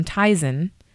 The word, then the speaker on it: Tizen: Samsung and Intel's operating system Tizen. Am I saying that right? Is that how you're saying it right.